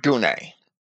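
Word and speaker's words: gune (γυνή): This word gune, and some people might pronounce that a little differently, trying to pronounce the U as an I would be pronounced, or as we pronounce the Y vowel in words like gynecologist, but I don't believe that it was pronounced that way by the Greeks.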